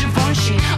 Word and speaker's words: Givenchy: Givenchy, it's pronounced Givenchy.